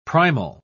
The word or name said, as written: primal